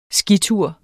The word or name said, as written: skitur